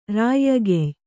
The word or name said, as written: ರಾಯ್ಯಗೆಯ್